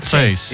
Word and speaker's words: Zeiss: Citur viņu rakstā kā Zeiss un tad citeri putrojās un izrunā. – Bet vācēšiem nu, viņš jau rakstās kā Zeiss. – Jā, bet izrunā Zeiss.